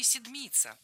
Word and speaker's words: седмица: А образовано наше слово по той же схеме, что и «седмица». Так раньше называли неделю. «Семь», а по-старославянски «седьмь». Седмица, сто, старица. Ударение в обоих словах падает на суффикс.